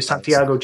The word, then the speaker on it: Santiago: Yeah, I'm going from Buenos Aires, Argentina, to Santiago, Chile.